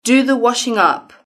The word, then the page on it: do the washingup: British English